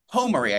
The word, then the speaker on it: Homary: Homary, I think it's pronounced.